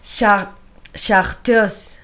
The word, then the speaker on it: chartreuse: El segundo formulario vamos a hablar del chartreuse. – a ver, chartreuse.